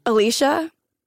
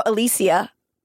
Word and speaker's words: Alicia: Alicia. We can go Alicia. Lol. – Is it spelled like Alicia? – Yeah. A-L-I-C-I-A.